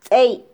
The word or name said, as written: tzey